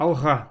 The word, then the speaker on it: алга: Алга. Ну, пишется как А-Л-Г-А. А. Ага. – А что, ну, а, а, это такой, как, ага. – такой Г, типа как Х, стремящийся. Ага.